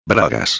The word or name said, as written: bragas